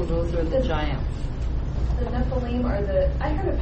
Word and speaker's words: Nephilim: It is Nephilim.